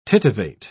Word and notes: tittivate: British English

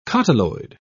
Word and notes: cotyloid: British English

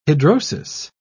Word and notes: hidrosis: British English

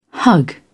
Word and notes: hug: British English

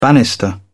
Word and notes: banister: British English

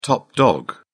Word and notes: top dog: British English

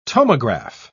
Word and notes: tomograph: British English

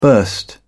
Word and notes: burst out: British English

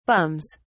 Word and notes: bums: British English

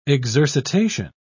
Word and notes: exercitation: British English

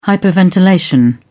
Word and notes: hyperventilation: British English

